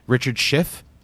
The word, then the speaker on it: Richard Schiff: Only like person I recognized was Richard Schiff. Is that how you say his name? – Yeah.